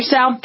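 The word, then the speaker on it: Seau: Now, he had suffered, so Sal, okay, Junior Sal, thank you.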